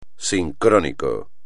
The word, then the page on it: sincrónico: espagnol